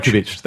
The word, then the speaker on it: Djokovic: Djokovic, that's right. We'll get the pronunciation right.